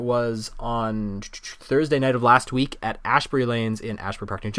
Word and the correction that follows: Asbury: It's Asbury, isn't it? I said Ash. – No, Asbury. Asbury.